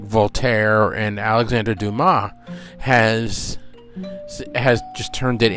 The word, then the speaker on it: Dumas: Well, it's D U M A S, but he's French, so it's pronounced Alexander Dumas.